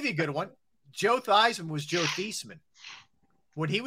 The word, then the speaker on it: Theismann: He said, we're going to change the pronunciation of your name to Theismann to rhyme with Heisman so you can try and win the Heisman trophy.